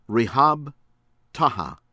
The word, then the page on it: Rihab Taha: American English; from Iraq